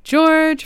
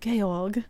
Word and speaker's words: Georg: And also, he's of German descent, and so I'm not sure if you would pronounce it like in The Sound of Music, like Georg.